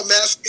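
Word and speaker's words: Más Agave: Asagave, did I say it right? – Oh, Masagave.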